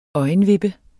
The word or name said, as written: øjenvippe